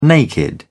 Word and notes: naked: British English

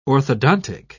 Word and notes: orthodontic: British English

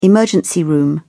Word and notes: emergency room: British English

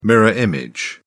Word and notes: mirror image: British English

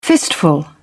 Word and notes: fistful: British English

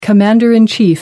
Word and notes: commander in chief: American English

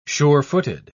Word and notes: sure-footed: British English

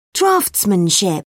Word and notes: draughtsmanship: British English

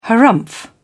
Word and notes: harrumph: British English

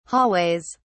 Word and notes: hallways: British English

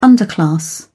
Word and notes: underclass: British English

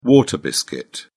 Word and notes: water biscuit: British English

